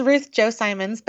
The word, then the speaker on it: Ruth Chou Simons: It's Ruth Chou Simons, but you would not.